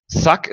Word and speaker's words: thug: Ähm, Thug ist auch ein sehr schönes Wort, was ähm, Deutsche schlecht aussprechen, weil äh, aufgrund der Auslautverhärtung, die Final Obstruent Devoicing im Englischen heißt, wird das gerne als K ausgesprochen, obwohl es ein G am Ende ist.